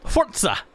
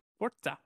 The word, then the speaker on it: Forza: I'm glad you're pronouncing Forza correctly. That's all I got. – Forza. – Forza.